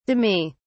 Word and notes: demi: British English

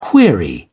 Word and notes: query: British English

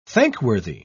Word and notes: thankworthy: British English

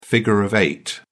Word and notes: figure of eight: British English

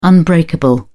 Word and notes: unbreakable: British English

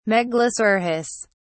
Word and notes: megalosaurus: British English

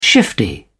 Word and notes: shifty: British English

